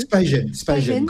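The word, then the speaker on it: SpyGen: Alors ce projet, il est aussi en partenariat avec Spigen. Est-ce que je l'ai bien prononcé ? – Spigen. Comme les espions.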